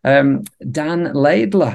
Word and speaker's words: Laidler: But even better than all that, we're joined by a special guest, Dan Laidler.